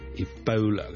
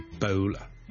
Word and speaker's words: Ebola: 跟最近，如果大家有睇国际新闻咧，都应该会留意到 Ebola 呢个伊波拉病毒咧，喺某啲地方肆虐噶。 Ebola 伊波拉病毒咧，我哋虽然广东话我哋译做伊。波拉波啦，我哋系 all 一个发音，但如果英文咧，我哋通常咧系读 Ebola 嘅 Ebola，煲煲，好似弓箭嘅弓嗰个煲，OK，咁所以咧唔系 Ebola， but Ebola， Ebola。